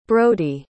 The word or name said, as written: brody